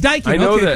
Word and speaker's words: Daikin: It's Daikin.